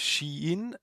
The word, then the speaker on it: Shein: Habe ich denn den Namen dieser Firma überhaupt richtig ausgesprochen? – Ja, genau. Es heißt Shein, weil es ein chinesischer Online-Händler ist.